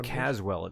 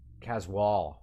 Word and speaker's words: Caswall: His name isn't Caswell, it's something else. – C-A-S-W-A-L. – Caswall. – Caswall. – We've been calling him Caswell.